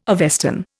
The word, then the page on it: Avestan: Indian